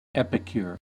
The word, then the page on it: epicure: American English